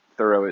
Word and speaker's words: Thoreau: I think Thoreau is how we pronounce it, but Thoreau is how it's supposed to be pronounced.